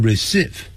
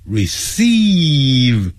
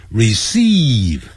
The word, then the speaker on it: receive: I'm glad to receive your letter，不要念成 receive， receive 啊，是拉长的音，长元音， receive，我很高兴接到你的来信。